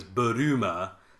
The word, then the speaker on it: Bulma: But because it's Japanese, her name is Buruma, so they just pronounce it Bulma. – Bulma. They're saying Bulma. – We got Bulma here. – So, but, you know, whatever. For us, it's Bulma.